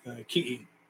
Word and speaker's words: ki'i: You know, the word tiki is actually the Tahitian word. So in Hawaiian, it's pronounced ki'i.